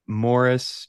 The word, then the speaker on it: Maurice: uh morris barry and fuck i'm forgetting the other one's name um but uh but yeah um also morris spelled like maurice pronounced Morris.